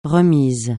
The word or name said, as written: réminiscence